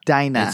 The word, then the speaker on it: Dina: It's pronounced Dina. – It's pronounced Dina. – I can promise you it's Dina.